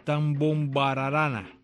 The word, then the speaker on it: Tambombararana: El título es Tambombararana. Tambombararana. Complicado. Si no lo separo sin sílabas, tam, con M, tambombararana. Así se llama.